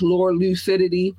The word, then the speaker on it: LURLUCIDITY: If you would like to drop a donation, $LURLUCIDITY, which means which is pronounced Lore Lucidity, Lore and Lucid to lure them in clearly.